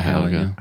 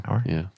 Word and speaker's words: Hauer: Hauer? – Rutger, Rutger Hauer. Hauer.